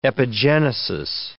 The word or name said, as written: epigenesis